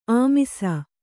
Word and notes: ಆಮಿಸ: kannada

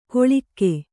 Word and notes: ಕೊಳಿಕ್ಕೆ: koḷike

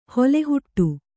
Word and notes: ಹೊಲೆಹುಟ್ಟು: hole huṭṭu

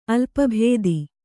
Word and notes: ಅಲ್ಪಭೇದಿ: kannada

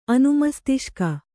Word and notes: ಅನುಮಸ್ತಿಷ್ಕ: anumastiṣka

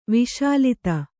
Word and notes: ವಿಷಲತೆ: viṣa late

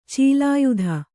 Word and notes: ಚಲಯುತ: calayuta

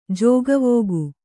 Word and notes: ಜೋಗವೋಗು: kannada

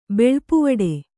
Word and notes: ಬೆಳ್ಪುವಡೆ: Kannada

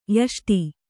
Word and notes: ಯಷ್ಟø: yaṣṭř

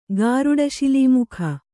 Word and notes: ಗಾರುಡಶಿಲೀಮುಖ: kannada

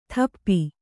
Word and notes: ಥಪ್ಪಿ: thappi

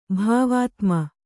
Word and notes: ಭಾವಾತ್ಮ: Kannada

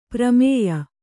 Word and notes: ಪ್ರಮೇಯ: kannada

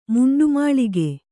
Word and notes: ಮುಂಡುಮುಳ್ಳುಗ: Kannada

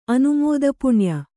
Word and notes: ಅನುಮೋದಪುಣ್ಯ: kannada

